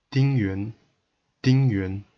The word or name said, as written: Ding Yuan